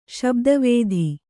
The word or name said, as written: ಶಬ್ದವೇಧಿ